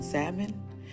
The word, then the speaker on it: salmon: She said, I really created it for it to be on salmon. And um, I, I, tried to get that right because I always say salmon, and they said that the L is silent, and that's stupid.